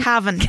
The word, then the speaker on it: Cavan: I love Cavan. Is that how you say his name? – Cavan. Like the county.